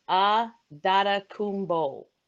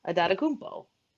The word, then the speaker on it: Adetokunbo: So, and it's funny because the pronunciation guide for the Bucks says "Ah Dada Kumbo" is how they phonetically put it. And I'm like, oh, well, that makes sense. Adada Kumbo.